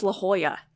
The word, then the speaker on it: La Jolla: She goes, it's pronounced La Jolla, which we never would have gotten.